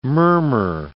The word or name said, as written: murmur